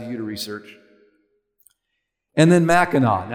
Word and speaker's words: Mackinac: Now, I have to admit, a couple days ago, I made reference to the Mackinac Bridge. And several of you have kindly corrected me. Thank you. So I'll make sure and say it correctly this morning. Mackinac.